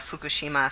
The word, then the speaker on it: Fukushima: So the book is called Tsunami versus the Fukushima 50.